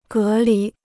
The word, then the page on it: 隔离: gé lí